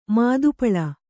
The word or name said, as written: ಮಾದುಪಳ